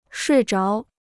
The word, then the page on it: 睡着: shuì zháo